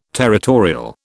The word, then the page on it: territorial: British English